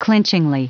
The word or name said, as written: clinchingly